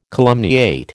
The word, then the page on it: calumniate: American English